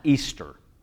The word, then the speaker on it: Ishtar: Now, I was told a long time ago that that word is pronounced Easter.